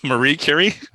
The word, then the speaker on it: Marie Curie: By Mary Curry. – Marie Curie? – I always say it wrong. By Marie Curie.